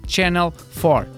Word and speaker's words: Channel 4: é uma produção do Channel 4.